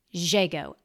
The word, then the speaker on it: Zhego: Z-H-E-G-O, Jago.